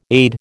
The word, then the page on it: AID: American English